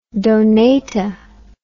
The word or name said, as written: Donata